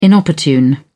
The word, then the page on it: inopportune: British English